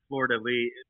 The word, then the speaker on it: fleur-de-lis: I love the fleur-de-lis.